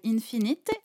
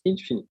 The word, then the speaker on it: Infinite: Alors, elle s'appelle Infinite ou Infini Infinite Alors ça, chacun voit comme il veut. Moi, je le prononce Infinite.